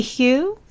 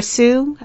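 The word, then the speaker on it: Hsu: And really, actually, I think, I'm sorry, it's Stephanie Hugh, or Sue, I think it's pronounced Sue, Stephanie Sue, mm-hmm.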